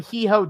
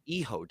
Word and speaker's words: hijo: No, H I J O Hee-Ho. – It's Eho, dude. – Hmm. – It's Eho. It's, you don't pronounce the H, it's Eho.